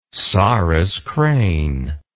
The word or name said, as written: sarus crane